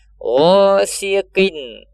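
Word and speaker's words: ghasiqin (غاسق): Gho. Dia huruf isti'ala. Dibacanya tebal. Gho. Bukan ra. Tapi ghasiqin, qin, idza, idza waqab, idza waqab, waqab.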